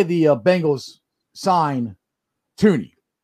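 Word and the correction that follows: Thuney: Or excuse me, Thuny. I gotta say his name right. It's Thuny, not Tooney.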